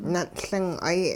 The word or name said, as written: nun'Ling'ay